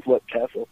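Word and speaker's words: Leap Castle: Was that where, now I'm probably going to mispronounce it, but was that where Lepp Castle was? – No, you're one of the few people that didn't mispronounce it. Yes, it was Lepp Castle. Usually people say Leap because that's how it's spelled, but yeah, that was in Ireland for sure.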